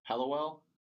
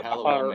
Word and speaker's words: Hallowell: Am I saying that right? Hallowell? – Yes, sir. – All right. – Yep, Hallowell, Maine.